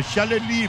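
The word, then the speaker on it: Chaalali: É Chalele, porque é francês.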